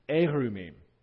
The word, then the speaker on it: erumim: Where it says they knew that they were naked, it's almost exactly the same word in 2:25, the one that's pronounced arumim. Here's where it gets nerdy. But it's pronounced in this place erumim. Just a slight little difference in how it's vocalized.